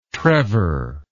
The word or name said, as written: Trevor